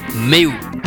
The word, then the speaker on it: Meu: Alors oui, ça s'écrit Me, mais ça se prononce Meu.